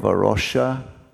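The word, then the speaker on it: A Rocha: And he said, in the work of Arosha, and well-pronounced Alexa, by the way, not everybody can do Portuguese, so it's a trick.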